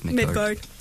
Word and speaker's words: Midgard: Yeah, it's Midgard.